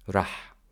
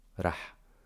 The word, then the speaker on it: رح: رح, there is no ألف, there is only فتحة. رح.